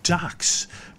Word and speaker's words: Duhks: They're called the Ducks, but they can't spell, John. They spell Ducks. It's spelled D-U-H-K-S, believe it or not.